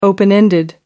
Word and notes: open-ended: American English

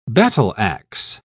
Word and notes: battle-axe: British English

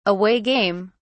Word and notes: away game: British English

